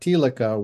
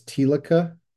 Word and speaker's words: Thielicke: And this is how you say that name. I think it's Thielicke, Helmut Thielicke. Of course, I don't speak German, so I don't know.